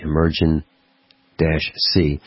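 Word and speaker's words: Emergen-C: That's spelled E-M-E-R-G-E-N dash the letter C. Emergen dash C.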